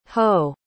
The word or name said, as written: hoh